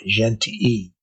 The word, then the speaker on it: Gentilly: If you're looking for how that is spelled, it's spelled like Gentili or Gentily, G-E-N-T-I-L-L-Y. Gentii, though, is the French pronunciation. At the time, it would have been a Frankish pronunciation, so probably Gentili, right?